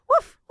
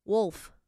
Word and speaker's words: Wolf: Woof. Oh, maybe that's why she changed it. – Do I say it wrong? Yeah. Wolf. – Wolf. – Wolf. – It has an L, Wolf.